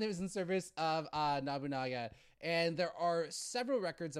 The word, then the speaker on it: Nobunaga: and none other were in the service of Nobunaga. That's what it is. I'm saying this wrong the entire time. I'm sorry. Nobunaga.